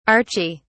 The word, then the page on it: archie: British English